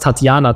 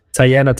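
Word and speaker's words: Teyana: Das habe ich sehr deutsch ausgesprochen. Fast Tatjana Taylor. Tiana Taylor.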